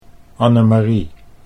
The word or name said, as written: Annemarie